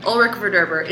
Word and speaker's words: Ulrich Verderber: Did I say it right? – Ulrich Verderber. It's a tough one. It's you nailed the first part.